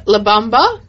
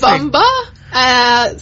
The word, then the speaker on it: La Bamba: And we played La Bamba, if I'm saying it correctly. – Yes, you are saying it correct. Perfect. – La Bamba.